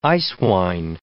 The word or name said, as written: eiswein